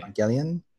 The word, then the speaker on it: Evangelion: I pronounce it Neon Genesis Evangelion. – I think that's pronounced correctly. Neon Genesis Evangelion. – Okay. – Evangelion.